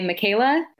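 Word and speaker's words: Mikaila: I am Magic by Michaela. It's M-I-K-A-I-L-A because my name is spelled a little weird.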